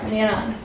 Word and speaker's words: Neeran: N-E-E-R-A-N. – N-E-E-R-A-N. – Yeah, you can think of it like this. Like me, Ron, roll your R. Miran.